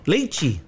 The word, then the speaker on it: lychee: It is pronounced uh, lychee. L A I C H E, or is that? Let us see. – Lychee. – There you go.